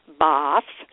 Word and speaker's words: Bath: Also a day trip to Bath, or as it's said occasionally over there, Bath. So I'll give it both pronunciations tonight. If you'd like to know more, and I got to tell you, I'm a dandy facilitator for this kind of thing.